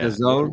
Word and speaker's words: DAZN: DAZN. – DAZN. – Is that how you pronounce it? DAZN? – Yeah. DAZN.